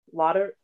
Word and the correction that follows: laudanum: laudan laudanum.